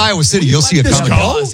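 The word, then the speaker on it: Kum & Go: Iowa also has Come and Go. – I know. Yeah, it's spelled in a weird way. – K-U-M. – It's really awkward. – Come and go.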